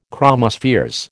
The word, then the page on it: chromospheres: American English